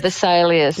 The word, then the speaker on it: Vesalius: The Vesalius, do I say that right? Vesalius? – Yeah, Vesalius.